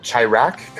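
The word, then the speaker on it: Chi-Raq: And then Spike Lee's new movie, I believe it's pronounced Chirac, comes out with John Cusack, Samuel L.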